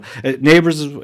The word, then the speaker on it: Nabers: Nabers is how they pronounce it, but it's spelled N-A-B-E-R-S.